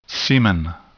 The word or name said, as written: semen